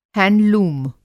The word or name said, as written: हैंडलूम